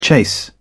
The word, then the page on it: chase: British English